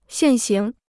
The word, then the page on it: 现行: xiàn xíng